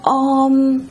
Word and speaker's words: Om: Họ phát âm chữ Om, họ kéo dài ra và Om, giống như là những cái tiếng gọi của chư thiên trong trời đất đó.